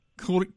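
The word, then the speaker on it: Couëron: In the town, is it Couron? How would you pronounce? I can't pronounce it. Couron? – Couron. – Couron. Now, you start there. – Couron. Yeah. Yes, it's a small village.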